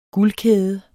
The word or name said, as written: guldkæde